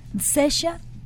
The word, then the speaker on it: Cesia: Y damos la bienvenida a nuestro programa a Sesha, si lo dije bien. – Sesha, claro. – Ay, el apellido te lo dejo a ti. – Hirschbein. – Oh, hacemos un muy buen equipo. – Bienvenida, bienvenida, Sesha.